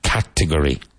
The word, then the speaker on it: category: Category. Emphasis on the first syllable.